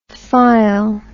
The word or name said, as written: phile